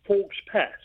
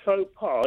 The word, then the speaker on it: faux pas: And he came across the expression faux pas, but he pronounced it Forks Pass. And the colonel said, no, the Duke rather said, no, it's pronounced faux pas.